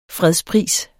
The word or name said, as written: fredspris